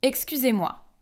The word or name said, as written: excusez-moi